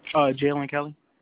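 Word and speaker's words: Jaylen Kelly: Jalen Kelly.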